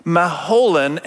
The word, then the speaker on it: Mahlon: The story of Ruth is about this couple, Elimelech and Naomi, who are living in Bethlehem, and they have two sons, Mahlon and Chilion. That's how you pronounce them.